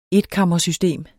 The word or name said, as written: etkammersystem